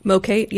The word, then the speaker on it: MOCATE: Mocate, yes.